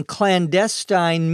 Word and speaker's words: clandestine: Sydney says, I wouldn't care if I could go on clandestine missions to thwart and sabotage the enemy. Clandestine, C-L-A-N-D-E-S-T-I-N-E, which can also be pronounced clandestine, refers to something that is secret, something that is hidden, especially if it is related to something that is wrong or criminal.